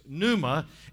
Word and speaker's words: pneuma: The P is silent, so it's pneuma, and it simply means a current of air.